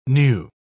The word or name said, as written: knew